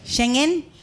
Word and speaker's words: Schengen: Like, so one, the first one was like the Shenzhen visa. How do you say it? – Schengen. – Schengen. Ah, I've been saying it wrong.